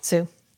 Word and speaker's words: Hsu: Well, her and Stephanie, her daughter in the movie, Stephanie Zhu, but Sue, it's HSU. How would you say that? – Sue.